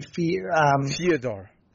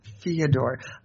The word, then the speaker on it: Fedor: Oh, what would you say? – Fyodor. – Fyodor. You add an extra syllable.